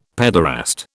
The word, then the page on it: paederast: British English